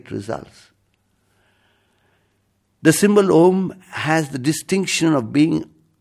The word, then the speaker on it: Om: In Sanskrit grammar, A and U coalesce to form owe. Thus, a, u, and m, a, u, and m together is pronounced as om. Even the mute can produce the sound of om.